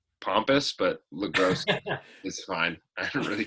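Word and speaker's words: Le Gros: And then in the other corner, in the other corner, weighing in as the amazing, legendary actor, Noah LaGrosse. Am I pronouncing that right? – It's close. It's LaGrosse. – LaGrosse, yeah.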